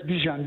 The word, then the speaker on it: Bijan: Sorry, did I say your name correctly? – Yes, Bijan. B-I-J-A-N, yes.